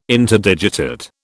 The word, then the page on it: Interdigitate: British English